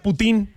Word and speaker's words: poutine: Se escribe poutine. – Ajá, ahí está el poutine, uh-huh, poutine, pero se pronuncia putín.